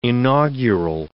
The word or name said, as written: inaugural